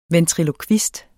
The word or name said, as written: ventrilokvist